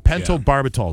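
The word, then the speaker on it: pentobarbital: Barbitol. Pentobarbitol is how you say it.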